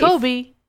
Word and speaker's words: Kobe: It's spelled Kobe as like in Kobe beef. Kobe.